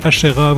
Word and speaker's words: Ashera: Salut, mon nom c'est Ashera, vous pouvez me retrouver évidemment sur les réseaux, ça s'écrit A-S-H-E-R-A.